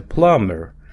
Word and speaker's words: plumber: не читается буква B, кстати, пишется plumber, а читается plumber.